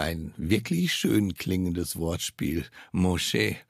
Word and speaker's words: Moshe: Moshe, so wird der Name auf Hebräisch ausgesprochen.